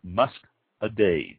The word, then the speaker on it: Muscadet: Muscadet. This is spelled M-U-S-C-A-D-E-T. T is silent, Muscadet.